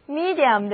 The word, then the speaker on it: medium: 处在中间的物质通常就是媒介物，所以 medium 的意思就是媒介物、工具或者是媒体。注意字母 e 的发音是长的 e，而字母组合 iu 的发音是 ear。